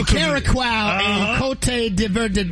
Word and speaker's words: Curaçao: Is it Curaçao? – Yeah. – Exactly. That's what I said.